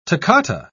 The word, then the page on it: toccata: British English